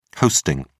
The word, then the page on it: hosting: British English